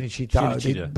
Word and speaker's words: Cinecittà: Cinecittà, how do you pronounce it? – Yeah, Cinecittà.